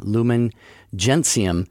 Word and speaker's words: Lumen Gentium: L-U-M-E-N is Lumen, and Gentium, G-E-N-T-I-U-M, Lumen Gentium. – That's right. – Is how you pronounce it, but it's a Latin, the Latin.